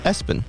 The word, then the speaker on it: ESPN: Mm-hmm. Pronounced Espen. – I worked with a kid whose name was Abcde. A-B-C-D-E.